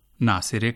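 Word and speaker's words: ناصره: سامعین, آپ نے یہاں جو جملے سنے ان میں خواتین کے جو نام ہیں رضیہ ناصرہ اور نسیمہ، ان کا فارسی تلفظ آپ نے ملاحظہ کیا کہ رضیہ کو رضیے کہا گیا، ناصرہ کو ناصرے کہا گیا اور نسیمہ کو نسیمے۔ ہم بتا چکے ہیں کہ یہ ہائے غیر ملفوظ کہلاتی ہے، اور اس طرح کے سبھی ناموں کو آپ اردو کی بڑی یہ کی طرح تلفظ کیجیے۔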